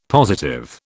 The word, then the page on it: positive: British English